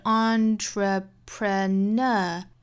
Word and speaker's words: entrepreneur: 后面 all the best entrepreneurs. 那这句话里面有一个词就是 entrepreneurs，这个词比较的难读哈。我们读的时候一定要注意音节，说好了音节是 entrepreneur. 那这个词一定要注意 entrepreneur 是有四个音节的。重读音节是在后面的 n 上面，我们知道这个词它本身是法语词啊。